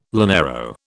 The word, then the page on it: llanero: British English